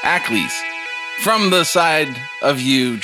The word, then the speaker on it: aklys: Comes flying a weapon that I know how to pronounce, but I'll spell it so that you can pronounce it. – Shillelagh. – No, it starts with an A. A-K-L-Y-S. – Ackles. – Ackles. – It's like a throwing stick. It's a Roman javelin. – A-C-K-L-E-S? – A-K-L-Y-S. – A-K-L-Y-S.